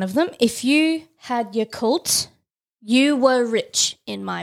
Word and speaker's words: Yakult: I don't know how to pronounce it. Is it Yakult? Yakult? – Yakult. – Yakult.